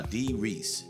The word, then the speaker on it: Dee Rees: Yeah, her name is Dee Reese. Now, I could not get that name wrong. – No, Dee Reese. D-E-E-R-E-E-S.